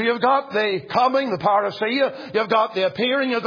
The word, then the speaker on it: parousia: Well, the word's pronounced parousia. Parousia.